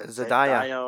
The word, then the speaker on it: Zendaya: Zendaya or something.